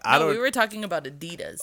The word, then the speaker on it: Adidas: As a moment to recognize that you said it right. – Because I like to say Adidas. – It's Adidas.